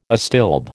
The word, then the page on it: astilbe: American English